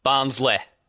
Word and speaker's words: Barnsley: Barnsley.